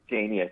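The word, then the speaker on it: GenYus: Now, do you say Genesis, Gen-Y-Us, or Genius? – It's just Genius. I mean, it's spelled Gen-Y-Us, but it's Genius,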